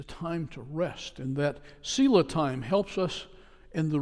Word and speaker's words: Selah: As you read this Psalm 62, you notice that verses 4 and 8 end with a word that is pronounced Selah, S-E-L-A-H.